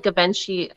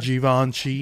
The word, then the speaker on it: Givenchy: So it's still a Givenchy, and I'm saying that wrong, by the way. Somebody in my chat is going to... – Givenchy. – There you go.